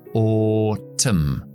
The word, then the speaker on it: autumn: Autumn la T oclusiva y sorda, o t, Es una palabra de dos sílabas y la sílaba que no acentúo lo dejo en el sonido neutro que se llama la schwa, o t Aunque es una U, se debilita y se deja como un sonido neutro, o-t-m.